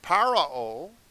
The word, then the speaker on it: Pharaoh: Remember how our ancestors were saved at the Red Sea, when Parao, that's the proper pronunciation of Pharaoh, with his forces pursued them.